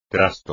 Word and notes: trasto: espagnol

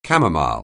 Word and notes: camomile: anglais

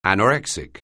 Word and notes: anorexic: anglais

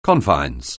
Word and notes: confines: anglais

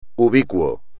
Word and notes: ubicuo: espagnol

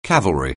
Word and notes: cavalry: anglais

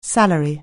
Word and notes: salary: anglais

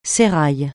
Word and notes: sérail: francais

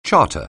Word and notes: charter: anglais

